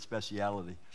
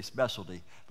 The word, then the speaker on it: speciality: I like that word, speciality. We Americans, we say specialty, but we British say speciality.